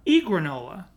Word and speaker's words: Egranola: Egranola. It's the word granola with an E at the beginning, so it looks very confusing.